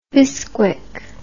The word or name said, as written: bisquick